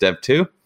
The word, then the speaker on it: dev.to: But then we also, everything is really centered around the platform, which we call dev2, which is dev.to, and it's pronounced dev2.